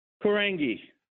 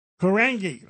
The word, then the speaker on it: Korangy: Tell me your pronunciation you? of your last name. – Korangy. Korangy, Amir Korangy, yes.